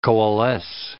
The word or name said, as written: coalesce